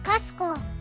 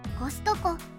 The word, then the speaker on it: コストコ: アメリカではコストコの発音をカスコと発音することが一般的で、日本のコストコの発音はキを省略していることから、英語圏の人々からは可愛く聞こえるようだ。